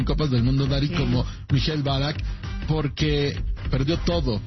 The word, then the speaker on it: Michael Ballack: ¿Cómo se dice bien? ¿Michael Ballack? – Michael Ballack, sí. – Michael Ballack, el futbolista que en ese momento jugaba en el Bayer Leverkusen, que había tenido una triste historia ese año.